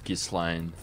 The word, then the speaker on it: Ghislaine: I believe it's pronounced Ghislaine.